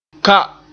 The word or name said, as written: ka